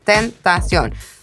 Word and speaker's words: tentación: So it's like three syllables, tentación.